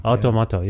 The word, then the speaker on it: Automato: I feel like Toronto figures heavily on this beautiful new By Divine Right album, which, correct me if I'm mispronouncing this, is it Automato? – Automato. – Automato, yeah.